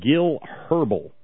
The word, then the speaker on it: Herbel: The best line, though, the best line out of this story is that of Representative Gil Herbel. Rhymes with gerbil.